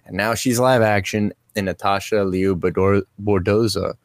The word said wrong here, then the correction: Bordizzo: That's not how you say it. Thank you. Thank you. Bordizzo crushes it.